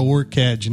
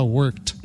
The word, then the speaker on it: worked: Não vai falar worked, né? Você vai falar worked. – Tudo que é no passado aí tem o ED no final, tira o E. Tiro na pronúncia. – Tira o E da pronúncia. – Worked, I work, I'm worked. Não, I worked. – I worked. I worked. Som de T, isso.